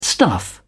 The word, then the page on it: stuff: British English